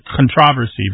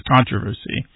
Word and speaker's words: controversy: And the famous one, which actually has a UK-US divide, although usually there is no US-UK divide, it's just a change thing, is controversy versus controversy. – Oh, yeah. – But even words like exquisite, harass, despicable, hospitable, they're all moving that pronunciation, that stress in the pronunciation, to the second syllable.